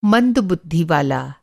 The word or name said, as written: मंद